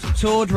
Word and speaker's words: Tord: Tord rhymes with board, which is what you would use to play a game of chess.